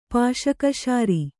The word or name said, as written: ಪಾಶಕಶಾರಿ